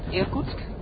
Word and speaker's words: Irkutsk: I think it's called Irkutsk.